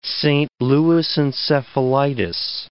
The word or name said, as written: Saint Louis encephalitis